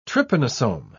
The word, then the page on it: trypanosome: British English